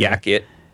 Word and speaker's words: Yakit: Is it like a... – Y-A-K-I-T, Yakit.